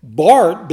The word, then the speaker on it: Barth: His name is spelled B-A-R-T-H, but it is pronounced Barth.